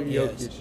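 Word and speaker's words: Jokić: Nikola Jokic? – Nikola Jokic.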